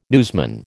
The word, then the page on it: newsman: American English